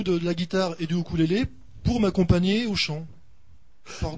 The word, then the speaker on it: ukulélé: On peut dire ukulélé. Normalement, c'est ukulélé. – Oui, parce que c'est la vraie UK, voilà. – Ouais, normalement, c'est ukulélé, c'est la vraie prononciation.